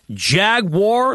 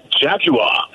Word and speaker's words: Jaguar: Is it Jaguar, like Star Wars, or Jagwire? – Well, I'm going to go with the British enunciation and Jaguar, because it just sounds so cool.